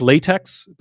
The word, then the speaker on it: LaTeX: I know it says LaTeX, but it's pronounced LaTeX. And if you say LaTeX to someone using LaTeX, they're going to immediately correct you. So here I am saving you. The next time you see LaTeX, pronounce it LaTeX.